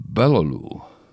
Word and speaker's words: Baloglou: Cherished grandma of Kyle, Alicia and Nick, N-I-C. A Turkish name here, Patricia Doris Balolu, B-A-L-O-G-L-O-U. The G is silent like P in Bath.